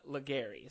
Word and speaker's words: Ligairi: But Ligairi is correct. There's an air right in the middle, A-I-R.